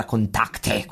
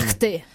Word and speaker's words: Kontakte: Un pezzo chiamato Contact. Ho sbagliato l'accento. – Ma no, ma chissà, no, perché allora, questo non lo so neanche, però in tedesco sarà Kontakte, qualcosa del genere. – Kontakte.